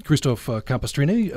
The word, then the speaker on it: Christoph Campestrini: Christoph Campestrini from Austria, and you're associated with Vienna Hofmusikkapelle? – Yes, it's true. – Did I get close on that? – Yeah, excellent. – Okay. – Perfect pronunciation.